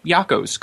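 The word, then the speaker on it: Yocco's: It's Yocko's.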